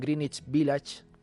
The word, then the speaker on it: Greenwich Village: En la obra, el protagonista es un muchacho de provincias que arriba a Madrid en busca de un Greenwich Village, porque se pronuncia así, que le convierta en una estrella de rock.